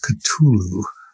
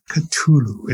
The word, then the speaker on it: Cthulhu: It's pronounced Cthulhu. Cthulhu.